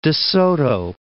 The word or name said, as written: De Soto